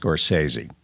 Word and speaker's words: Scorsese: It's pronounced Scorsese.